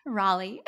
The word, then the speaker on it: Raleigh: Raleigh.